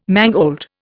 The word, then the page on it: mangold: Indian